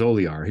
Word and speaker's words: Olear: And the guy who opened the door, first of all, he's pronounced my name correctly, which is Oliar.